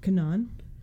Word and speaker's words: Canaan: Canaan. It's like Can-an. A-N-A-A-N. And I definitely made mental note to look this up earlier. Completely forgot. So C-A-N-A-A-N? – Yes.